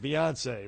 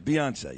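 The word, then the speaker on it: Beyoncé: Well, how do you pronounce it? Beyonce, right? – Yeah, Beyonce, yeah.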